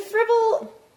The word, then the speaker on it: frivol: To frivol. – Basically, it's to act frivolously. Did I say that right? – Yes, and a derived form from that is to frivol away.